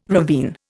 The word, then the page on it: ravine: Indian